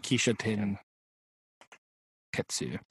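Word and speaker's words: Kishōtenketsu: Kisho. And the O has like a line over it if you want to go for the long vowel sound. Kishoten Ketsu.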